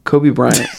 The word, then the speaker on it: Kobe Bryant: Like, notoriously, like, Kobe O'Bryant was, like, did I say that right? Am I just drunk? Kobe Bryant. Kobe, and I said O'Bryant. Kobe Bryant.